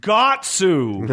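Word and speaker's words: Guts: And so now Band of the Hawks is going to say, it's pronounced Gatsu.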